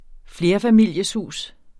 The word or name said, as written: FLERFAMILIESHUS